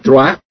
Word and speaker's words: Trois: Se escribe Trois. Así, T-R-O-I-S. Que en francés, más o menos se pronuncia Trois. Que es tres en francés.